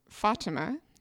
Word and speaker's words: Fatima: So she writes in very kindly to let us know that it's Fatima. Accent on the first syllable and then very soft T and I sounds.